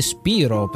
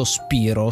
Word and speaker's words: Spyro: Spiro, che viene detto così perché è come si legge in italiano. E questa è una cosa, non è un errore vero e proprio, ma è una interpretazione italiana, perché deve essere facile da leggere per i bambini che non sanno ancora l'inglese.